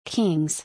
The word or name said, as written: Kings